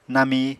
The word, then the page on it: nami: Apatani